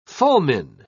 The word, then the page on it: fulmine: British English